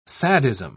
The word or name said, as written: sadism